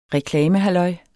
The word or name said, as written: reklamehalløj